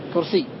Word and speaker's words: كرسي: يعني الآن نطقت بياء بعد الياء المشددة أو بياء مشددة فقط؟ – بياء مشددة فقط، كرسي. – نعم أحسنت، كرسي.